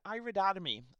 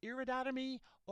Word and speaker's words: iridotomy: It can be pronounced either iridotomy or iridotomy. I really couldn't determine which one is preferable, so I'll stick with iridotomy because it refers to the iris of the eye.